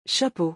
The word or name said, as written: chapeau